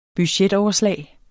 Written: budgetoverslag

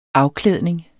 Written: afklædning

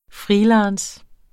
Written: freelance

